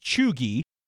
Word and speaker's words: cheugy: The word is spelled, I'll spell it a couple times, C-H-E-U-G-Y. That's C-H-E-U-G-Y. And this is a word that I came across at some point this year, and I did go ahead and look it up because I had no idea what was going on. And it's pronounced chugy. – Correct.